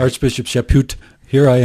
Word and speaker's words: Chaput: Do you spell it? – Chaput. – Caput. – No. – Okay. No. Anyway, Chaput.